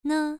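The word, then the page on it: ne: tone 1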